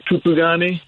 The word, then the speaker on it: Kupugani: Well, I pronounce it Kupugani. – Kupugani. Okay. – Zulu term of it originally. I heard someone pronounce it like with some Zulu flair, which I don't speak, but that's as close as I get.